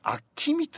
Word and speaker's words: 陽水: あれさ、陽水って書いて本名なんだよね、あきみって言うんだね、あきみって読むんですね、同じ字を書いて、